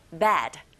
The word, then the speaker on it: bed: 이 침대, bed. 자, bad. 이렇게 발음하시고, 나쁜이란 뜻의 형용사는 bad, bad. 이렇게 발음하시면 됩니다. 철자 다르고 소리도 비슷한 것 같지만 달라요.